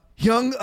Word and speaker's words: Jung: Young is how you pronounce it. J-U-N-G.